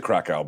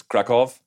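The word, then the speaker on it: Kraków: I took a train from Berlin to, is it, I pronounced it Krakow, but it's Krakow.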